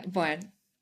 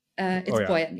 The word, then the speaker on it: Boyan: Like, how did you start off in the industry and how did you get into working with your two co-founders, Nick and Boyan? How do you pronounce his name? – Yeah. Boyan. Boyan. Yeah. You actually did it perfectly.